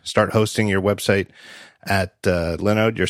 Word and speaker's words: Linode: I used to call them Linode because that's what it looks like to me, Linode, but it's actually pronounced Linode because it's a node, like a thing, like a server, and they host Linux, which is pronounced Lin.